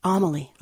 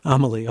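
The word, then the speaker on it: Amélie: last season's Amelia, which is, did I say that right? – Amelie. – Amelie. Okay, thank you.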